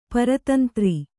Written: ಪ್ರತೀಂದ್ರ

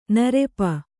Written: ನೆರಪು

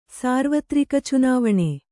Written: ಸಾರ್ವತ್ರಿಕಚುನಾವಣೆ

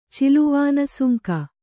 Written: ಚಿಲುವಾನಸುಂಕ

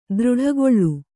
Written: ದೃಢಗೊಳ್ಳು

